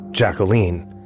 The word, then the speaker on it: Jacqueline: First, yes, it is pronounced Jacqueline, like Queen.